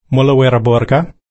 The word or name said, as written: mullawirraburka